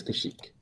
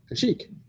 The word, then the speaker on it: Kashyyyk: It's Kashyyyk. – Kashyyyk.